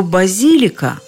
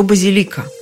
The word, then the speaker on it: базилика: Откуда же взялось это колебание в ударении? То базилика, то базилика.